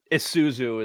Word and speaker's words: Isuzu: The Australians say Isuzu. Is that what you guys say here? Or do I just say it wrong? – I think normally Isuzu is the way that I have pronounced it.